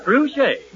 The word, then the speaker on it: Trushay: Harry, did you say truché? – Like she never heard of it before, some actress. – Yes, yes, Nora, truché. – Now he'll spell it. – T-R-U-S-H-A-Y.